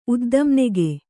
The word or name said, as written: ಉದ್ದಂನೆಗೆ